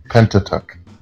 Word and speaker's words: Pentateuch: I don't know how to pronounce it either. The Pentateuch, the Pentateuch, the Pentateuch. – Pentateuch. – Mm-hmm, that one.